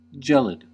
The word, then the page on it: gelid: American English